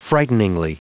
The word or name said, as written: frighteningly